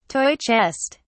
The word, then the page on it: toy chest: British English